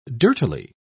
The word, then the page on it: dirtily: British English